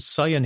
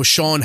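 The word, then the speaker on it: Siân: No, Sean Heder. – Is it Sean as I pronounce her name? Okay, it's written really weird. And she's only done it's the Welsh Welsh spelling.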